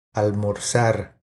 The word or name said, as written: Almorzar